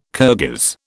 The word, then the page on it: Kirghiz: British English